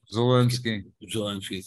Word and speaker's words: Zelensky: whose name I can never pronounce. – Zelensky, thank you.